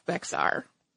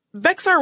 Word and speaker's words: Béxar: And if you are a Spanish speaker, you can probably roll your R at the end of that beautifully. If I try to do that, I sound like a child practicing Spanish for the first time, so please pardon my American pronunciation of Béxar.